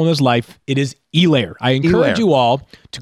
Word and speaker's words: Helaire: Is it Clyde Edwards-Helaire or Helaire? You're saying the H is silent, Field.